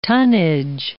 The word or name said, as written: tonnage